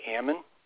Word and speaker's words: Ammann: It's actually Ammon. – Ammon. And that is A-M-A-N-N. So A-M-M-A-N-N.